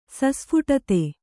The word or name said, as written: ಸಸ್ಫುಟತೆ